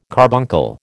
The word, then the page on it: carbuncle: American English